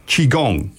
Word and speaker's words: Qigong: I'm going to have a crack at the pronunciation here because it's spelled Qigong, but we pronounce it Qigong.